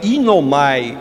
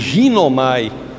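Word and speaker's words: inomai (γίνομαι): Em grego, a, a palavra é inomai, ou como pronuncio em português, ginomai.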